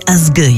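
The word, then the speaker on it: Ásgeir: La version qu'on vous propose ce soir nous vient d'Islande et d'un artiste électro-folk délicat, Asgeir, qu'il faut prononcer Asgeir, il paraît.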